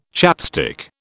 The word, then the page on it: chapstick: British English